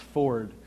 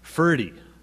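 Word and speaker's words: Forde: It looks like it should be pronounced Ford, but I have it on good authority from someone who lives in the Minnesota area that it's actually Fürde, Gerhard Fürde, who is a Lutheran theologian.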